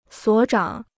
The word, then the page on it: 所长: suǒ cháng